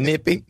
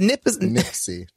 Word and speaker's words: Nipsey: And you kept saying Nippy. – Nippy. – How do you say it? – Nipsey.